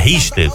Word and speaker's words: Richter: O Richter, Richter, como é que fala? – Richter.